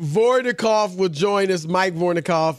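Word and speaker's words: Vorkunov: I think I destroyed his name. – Wait a minute. What is his name? – How you say his name, Rob G? – Hey, Rob G. I know. How do you say that? – Mike Vornikov, friend of the show.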